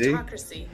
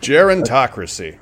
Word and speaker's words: gerontocracy: And it is pronounced... – Gerontocracy. – Old. – Hang on a second. – Gerontocracy.